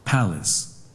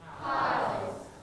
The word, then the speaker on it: palace: Palace. – One more time. – Palace. – Everybody together, 3, 2, 1. – Palace. – I put the pink color in the first syllable. ครูใส่สีชมพูไว้ให้ที่พยางค์แรกนะครับนักเรียนปลายทางด้วยแสดงว่าคำนี้ stress หรือลงเสียงหนักที่พยางค์ที่หนึ่งนะครับเราจะอ่านว่า palace, palace. ไม่อ่านว่า palace แบบนี้ไม่ใช่นะครับเพราะว่าสีชมพูอยู่ที่พยางค์ที่หนึ่งนะครับ first syllable.